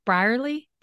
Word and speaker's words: Brierley: And then someone you've introduced me to is Justin, I'll say his last name wrong, Brierley. I said it right.